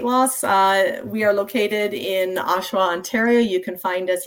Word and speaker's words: Oshawa: Tell us about, how can people find you ladies if they are in Ontario, if they are in Oshawa? Did I say that right? – You did. Oshawa. You said it right.